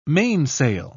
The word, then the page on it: mainsail: British English